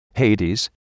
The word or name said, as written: Hades